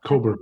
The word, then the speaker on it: Kohberger: The reason why I want to bring that up is because of like the Idaho murders, Brian Koberger seems to be, am I pronouncing his name right? – Yeah, Koberger.